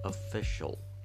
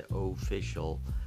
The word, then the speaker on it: official: official ولی اون چیزی که من در ابتدا گفتم تلفظ استاندارد official هست اگه دقت کرده باشین من میگم official شل پس شیال تلفظ من نمی کنم